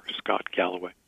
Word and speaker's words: Galloway: Scott Galloway.